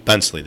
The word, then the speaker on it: Bentzley: How do you say your last name? Bentley? – Bensley. – There's a Z in there.